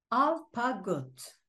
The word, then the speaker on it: Alpagut: Alpagut.